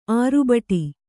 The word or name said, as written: ಆರ್ಭಟ